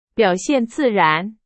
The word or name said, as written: 自然